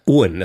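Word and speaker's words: Aaen: Arn. – Ohn, das ist eigentlich die alte dänische Schreibweise, die Doppel-A wie O.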